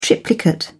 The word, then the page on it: triplicate: British English